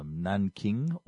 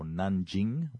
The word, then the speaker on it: Nanjing: Imperial China signed the Treaty of Nanking or Nanjing, whichever one of the two pronunciations you want to use, you'll be okay. You'll be correct.